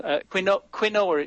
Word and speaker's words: quinoa: Quinoa. It's a Spanish pronunciation. Quinoa, it's spelled, but it's pronounced quinoa because everybody seems to carry the Spanish pronunciation of the word.